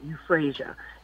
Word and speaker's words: euphrasia: And one of the first remedies that's helpful for allergies is a remedy called euphrasia. And it's spelled E-U-P-H-R-A-S as in Sam, I-A, euphrasia.